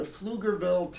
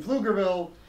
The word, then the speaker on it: Pflugerville: Apparently, he paid $600 for an airline ticket for Geno Smith to go to Pflugerville, Texas, which is spelled with a P.